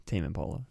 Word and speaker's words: Tame Impala: Did I say that correctly? – Yeah, Tame Impala.